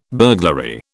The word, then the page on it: burglary: British English